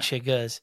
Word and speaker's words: chiggers: Well, we say it with an A, so it's a bunch of chiggas.